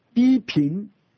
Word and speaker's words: Pippin: 到了主后第七世纪的末叶，就是 687年，有一个年轻有为的人，名叫批评，还是说 P-I-P-P-I-N，批评有不同的发音。